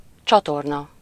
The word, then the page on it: sewer: /ˈsuːə/; /ˈsjʊə/; /ˈsuɚ/; /ˈsjuːə/; /ˈsəʊə/; /ˈsoʊə/